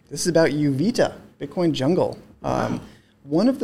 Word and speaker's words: Uvita: I think I'm saying it right. Uvita, U-V-I-T-A.